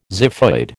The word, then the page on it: xiphoid: American English